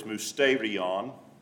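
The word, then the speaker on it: mousterion (μυστήριον): The pronunciation of that Greek word is mousterion.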